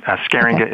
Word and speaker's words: Scheeringa: Scheeringa is spelled S-C-H-E-E-R-I-N-G-A.